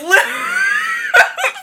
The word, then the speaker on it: Looped Up: Looped! – Looped up. – Looped! Okay. Did I not pronounce it? – I thought you said lubed up, and I was like, oh.